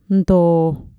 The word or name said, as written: ntoo